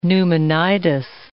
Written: pneumonitis

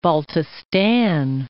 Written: Baltistan

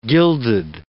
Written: gilded

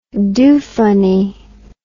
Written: doofunny

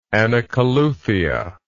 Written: anacoluthia

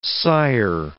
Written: sire

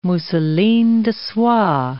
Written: mousselines de soie

